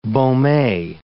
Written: Baume

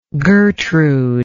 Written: gertrude

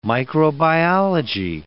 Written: microbiology

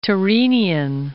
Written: Tyrrhenian Sea

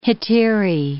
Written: hetaerae

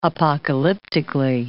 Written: apocalyptically

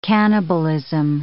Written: cannibalism

